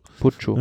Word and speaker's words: Puccio: Habe ich ihn richtig ausgesprochen? Salvatore Puccio? – Ja. – Puccio? – Puccio.